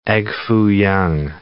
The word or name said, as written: egg foo yong